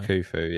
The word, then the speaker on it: Khufu: Khufu? I don't know. – Khufu, yeah.